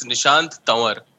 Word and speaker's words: Nishant Tanwar: Hey, Aakash, it's Nishant Tanwar.